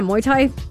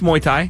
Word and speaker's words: Muay Thai: Was just talking about a Muay Thai fight. Did I say that right? Muay Thai fight? Muay Thai? – No, it's Muay Thai.